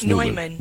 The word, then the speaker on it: Neumann: Is it Newman or Neumann? – It's Neumann. Neumann. Okay. Yeah, I think that's how they say it to keep him separate, right? – Yeah, that's right.